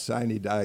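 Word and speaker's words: sine die: Murphy was speaker, but you said it's pronounced Signy Die, y'all.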